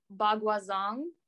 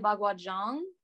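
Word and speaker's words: Baguazhang: They're definitely like so the way it's spelled, I would read it as like Bagua Zong, but then I think the ZH is more like a J sound, because the teacher will say Bagua Zhang, but I really don't know, and so I don't want to act like I know what I'm talking about.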